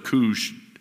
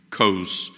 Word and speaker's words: Kos: In the Greek, those two O's are really one letter. It's omega. And you can always tell what the vowel in Greek, how you should pronounce it by the way of its name. See, so Omicron, ah, would be a short O, but Omega, you see, you use the long O there, at least in English. So instead of Kush or Kus, this would probably should be Kos.